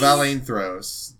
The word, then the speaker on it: Valeinthros: Valaine Throws, 2015.